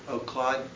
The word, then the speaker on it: Claude: Claude, it's like C-L-A-U-D-E. – Like the name. – Like the name. – Not like cat claws.